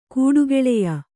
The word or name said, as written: ಕೂಡುಗೆಳೆಯ